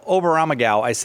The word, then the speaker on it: Oberammergau: So, well, first of all, Oberammergau, did I say it right? – Yes.